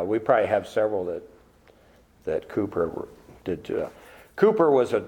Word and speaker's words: Cowper: It looks like Cowper, but it's pronounced Cooper.